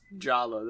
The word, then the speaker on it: Jaller: Number one, yeah, actually, to answer your question, you pronounce Jaller as Jala. The pronunciation doesn't change.